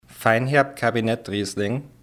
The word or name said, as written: Feinerb Kabinett Riesling